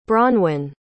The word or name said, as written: bronwyn